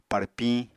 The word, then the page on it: párpiñ: apatani